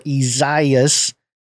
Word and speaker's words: Isaias: Isaias, pronounced Isaias, I guess I was saying it wrong.